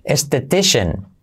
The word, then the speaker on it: esthetician: Ah, siguiente, estético, esteticien, que se dice en español, esthetician. Esthetician, alright? Esthetician, letra clave SH al final, ¿vale?